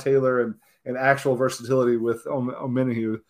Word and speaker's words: Omenihu: That's what they did with Charles Omen. All right, give me a pronunciation. – Omenihu. – I'm not used to it yet. Charles Omenihu.